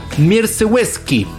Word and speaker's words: Mierzewski: Ay, asume Sergio Mierzewski. Se escribe Mierce con Z. – ¡Viva Chile, Mierzewski! – Es como Lewandowski, pero Mierzewski. Así que mis respetos a él, que su nombre es pésimo.